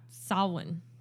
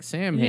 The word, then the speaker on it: Samhain: Well, Samhain. – Okay. – What's it spelled? Like, it has an H in there, but... – Yeah, it's spelled S-A-M-H-A-I-N, so it looks like Samhain, yeah. But it's Samhain.